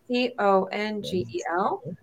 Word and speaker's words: Congel: J O E last name C O N G E L.